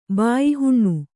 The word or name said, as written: ಬಾಯಿಹುಣ್ಣು